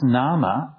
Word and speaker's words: name: And interestingly enough, the English word we pronounce today as name would have been pronounced nama up